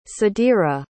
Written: sadira

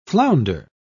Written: flounder

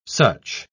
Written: such

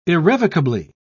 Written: irrevocably

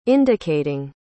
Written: indicating

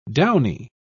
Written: downy

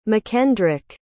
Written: mckendrick

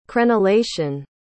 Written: crenellation

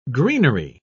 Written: greenery

